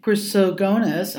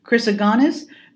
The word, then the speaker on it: Chrysogonus: In any case, the Cardinal Peter of St. Chrysogonus, oh my god, I know I didn't say that right, Chrysogonus,